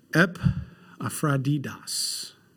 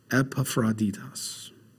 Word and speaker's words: Epaphroditus: Yet I consider it necessary to send you to Epaphrodidus. That's how we say his name in the Greek correctly. Epaphrodidus, my brother and fellow worker and fellow soldier, but your messenger and the one who ministered to my need.